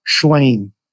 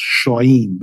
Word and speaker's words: Shlaim: Avi Shlaim, his name is. S-H-L-A-I-M. In Hebrew, it's probably pronounced Shoim, but he lives in Britain.